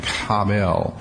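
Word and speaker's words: habel (הֶבֶל): It's one of those words that you kind of have to have a little cough in your throat to say it correctly, habel.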